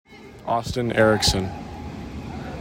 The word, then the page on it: Austin Erickson: AW stin ERR ick sin